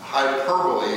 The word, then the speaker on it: hyperbole: And the accent is over the per. Hyperbole.